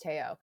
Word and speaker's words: Theo: That would be T-H-E-O, but pronounced Teo.